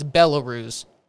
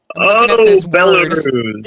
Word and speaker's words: Belarus: No, I just said Belarus. It's Belarus. That's how you pronounce that. – Belarus.